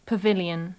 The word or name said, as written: pavilion